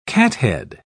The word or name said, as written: cathead